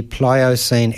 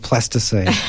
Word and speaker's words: Pliocene: And she is best known for her books, The Saga of the Pliocene Exile, which I'm absolutely gobsmacked that I actually managed to pronounce Pliocene and not Plasticine.